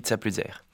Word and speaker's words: Tsapuzaïr: Eh bien ça c'est comme vous voulez, ça se prononce comme c'est écrit, mais nous, moi je dis Tsapuzaïr.